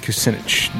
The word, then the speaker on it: Kucinich: Look up Kanukchik or however you say his name. I've never been able to say it. – Kucinich? – Kucinich. Dennis Kucinich.